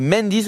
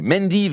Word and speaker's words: Mandy: Allez, Mendy, ça s'écrit M-A-N-D-Y. Mendy, virgule Indiana, avec Pinking Shears.